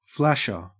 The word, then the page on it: flasher: Australian English